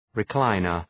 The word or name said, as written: recliner